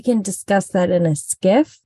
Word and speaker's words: SCIF: Listen, we'll skiff about over donuts. – It. Exactly. So I looked it up and it's spelled SCIF, and it stands for Sensitive Compartmented Information Facility.